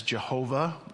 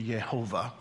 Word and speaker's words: Yehovah: And so where it says here in Psalm 23, it says Lord in all caps, and we would translate that as Jehovah, or in the more proper Hebrew pronunciation, it would be Yehovah.